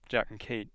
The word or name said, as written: jack